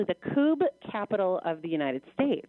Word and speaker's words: kubb: National Coob Championship. – Coob. – Coob. That's spelled K-U-B-B. Coob. – So it looks like cub, but it's coob.